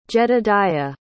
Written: jedadiah

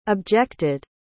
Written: objected

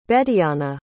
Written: Betiana

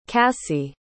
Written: kassie

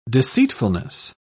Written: deceitfulness

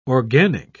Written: organic

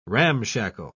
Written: ramshackle

